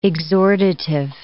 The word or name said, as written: exhortative